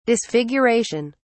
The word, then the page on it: disfiguration: British English